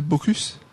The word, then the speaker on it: Baucus: Faut prononcer le S à Baucus.